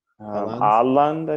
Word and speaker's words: Haaland: Haaland's. How do you say that name, guys? – Haaland, I think. Yeah. – Yeah, Haaland.